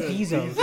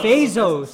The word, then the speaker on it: Bezos: You said Bezos. – that's how you say it, right? – No, Bezos.